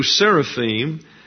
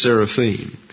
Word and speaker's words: seraphim: And then verse 2, seraphim, I am is a plural ending in Hebrew. And so in English it would be seraphs, if you want to pronounce it in English, and then in Hebrew it's seraphim.